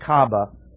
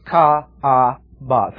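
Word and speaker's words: Kaaba: And therefore, they made an enclosure that was called Ka'aba, K-A-A-B-A-H. In the Semitic languages, almost all the time, every syllable is pronounced. So this is not Ka'aba, but two syllables, Ka'aba, three syllables, right?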